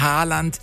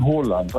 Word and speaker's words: Haaland: Erling Haaland ist falsch, ne? – Ja, das ist absolut falsch. Er wird ausgesprochen Haaland, also mit O. – Ja. – Haaland, und zwei A's werden als O ausgesprochen und ein A mit einem Kringel oben drauf ist eigentlich auch eine Aussprache, ein O.